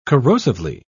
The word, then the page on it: corrosively: British English